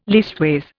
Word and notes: leastways: Indian

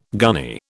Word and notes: gunny: British English

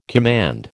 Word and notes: command: American English

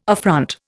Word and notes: affront: Indian

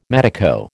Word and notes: medico: American English

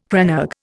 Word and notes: crannog: Indian

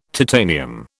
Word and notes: titanium: British English

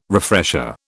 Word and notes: refresher: British English

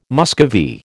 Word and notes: Muscovy: American English

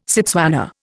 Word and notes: Setswana: Indian

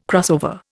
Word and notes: crossover: Indian

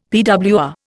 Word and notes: PWR: Indian

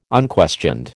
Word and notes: unquestioned: American English

